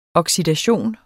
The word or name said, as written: oxidation